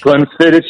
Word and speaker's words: Glenfiddich: Glenfiddich? Is that how you say it? – Is that how you say that? Glenfiddich? – Glenfiddich, yeah, but that's a single malt scotch.